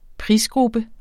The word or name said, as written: prisgruppe